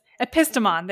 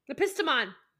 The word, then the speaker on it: Epistemon: So it's Philomathes and Epitismon. No, okay. – I practiced this. Epistemon, there it is. And guess which one is the smart one? – Epistemon.